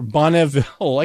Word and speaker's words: Bonneville: So bsuits at Bonneville or Bonneville. I guess it is pronounced. It's the first E is pronounced.